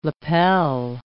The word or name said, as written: lapel